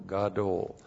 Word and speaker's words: gadol: Looks like that in the Hebrew, G-A-D, long O-L, gadol.